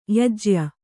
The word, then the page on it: ಯಾಜ್ಯ: kannada